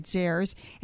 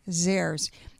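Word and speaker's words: Zehr's: There's so many different varieties that you can find at Zares, and that's spelled Z-E-H-R apostrophe S, Zares.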